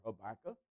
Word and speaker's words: Habakkuk: Several pronunciations for that name, but I prefer Habakkuk.